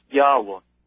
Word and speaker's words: Yahweh: They say, quote, the divine name, YHWH in Hebrew characters, was probably pronounced Yahweh.